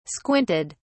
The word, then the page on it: squinted: British English